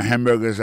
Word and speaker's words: hamburger: N'a pas remarqué que dans le mot hamburger, ça a comme tu as dit, il y a trois syllabes là-dedans. Quand tu appuies et puis plus ça, c'est sous premier syllabe là.